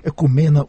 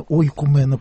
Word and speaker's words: ойкумена: Экумена, ойкумена произносится иногда по-разному. Напоминаю, это по-гречески от слова «экос», «ойкос», «дом».